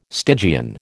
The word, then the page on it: Stygian: American English